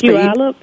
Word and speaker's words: Puyallup: You just had something in Palio. What was going on there? – I'm sorry? – You said, see, I don't even know how to say the name of that place. – Puyallup? – Puyallup, yeah.